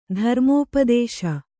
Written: ಧರ್ಮೋಪದೇಶ

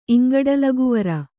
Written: ಇಂಗಡಲಗುವರ

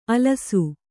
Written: ಅಲಸು

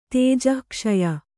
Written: ತೇಜಃಕ್ಷಯ

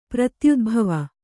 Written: ಪ್ರತ್ಯುದ್ಭವ

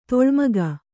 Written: ಧೂಳಿಮುಕ್ಕು

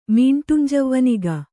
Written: ಮೀಂಟುಂಜವ್ವನಿಗ

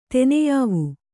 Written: ತೆನೆಯಾವು

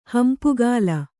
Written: ಹಂಪುಗಾಲ

